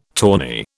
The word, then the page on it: tawny: British English